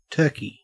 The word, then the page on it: turkey: Australian English